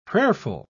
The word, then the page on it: prayerful: British English